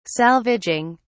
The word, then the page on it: salvaging: British English